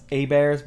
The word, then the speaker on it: Abears: It's pronounced A Bears, but it's spelled A-B-E-A-R-S, and they put gummy bears at the bottom,